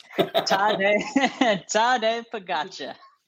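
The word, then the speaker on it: Tadej Pogačar: Spectre is on Tajay Sharp. How do you pronounce his name? – Tade Pogacar.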